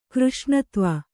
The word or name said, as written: ಕೃಷ್ಣತ್ವ